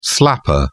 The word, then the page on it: slapper: British English